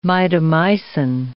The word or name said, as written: mitomycin